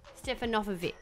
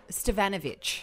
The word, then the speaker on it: Stefanovic: Stefanovkovic. – Stevanovic. – Oh, there you go. – Because it's the emphasis that wasn't like Carl Stefanovic's last name, because that's been Aussieified.